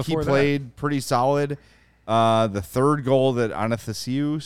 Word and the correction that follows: Athanasiou: scored, did I say that right? – Athanasiou.